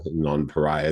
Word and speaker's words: Nonpareil: And that's where the best known variety, Nonpareil, Nonpareil is the way we would pronounce it if we'd taken high school French, was created by an almond grower way back in the 1870s.